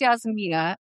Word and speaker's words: Yasmina: So Yasmina. Yeah. Did I say it right? – You said it right.